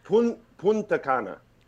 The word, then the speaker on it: Punta Cana: How would you say it? – Punta Cana. – Punta Cana. – There's an N in there, yeah.